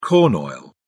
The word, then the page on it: corn oil: British English